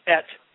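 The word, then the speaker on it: et (את): In Hebrew, that would be pronounced et.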